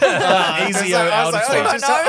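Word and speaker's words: Ezio Auditore: That's the way you pronounce it. Ezio Auditoire.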